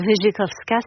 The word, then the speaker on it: Wierzykowska: Inga, donc vous êtes d'origine polonaise, est-ce que vous souhaitez qu'on prononce votre nom à la française, donc comme il s'écrit pour nous français, Wierzykowska, ou à la polonaise, Wierzykowska? Puisqu'il y a RZ, comment vous souhaitez qu'on... – Vous dites très bien en polonais, donc Wierzykowska, c'est parfait.